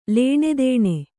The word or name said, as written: ಲೇಣೆದೇಣೆ